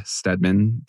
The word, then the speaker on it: Stedman: Stedman.